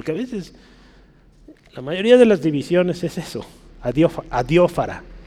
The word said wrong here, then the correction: adiáfora: otra vez. Adiáfora. Adiáfora. Este, hay un acento en la adiáfora.